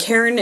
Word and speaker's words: Karon: And Caron is spelled K-A-R-O-N, just so that it's not confusing to people.